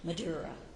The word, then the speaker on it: Maduro: He's kind of like Madeira. Is that how you say it? Maduro.